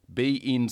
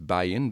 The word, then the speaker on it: beIN: Foxtel will launch three, I think it's beIN, B-E-I-N, I suppose that's the way I'm pronouncing it, right?